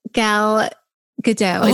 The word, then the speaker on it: Gal Gadot: Okay, I would choose Gal Gadot.